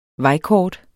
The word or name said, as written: vejkort